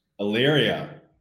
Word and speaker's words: Elyria: Elyria.